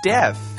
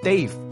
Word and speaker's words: deaf: 那第二个形容词 deaf deaf D-E-A-F D-E-A-F deaf，它不念 dave 也不念 deaf，念作 deaf，是个形容词，表示聋的或者是听不到的。